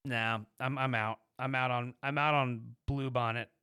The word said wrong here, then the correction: Brute Bonnet: Brute Bonnet.